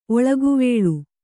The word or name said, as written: ಒಳಗುವೇಳು